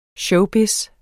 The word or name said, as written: showbiz